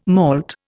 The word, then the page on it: malt: Indian